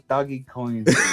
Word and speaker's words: Dogecoin: No, dude's like, no, it's doggy coins.